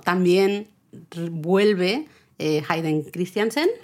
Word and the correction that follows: Christensen: Christensen. – Christensen, siempre lo digo mal.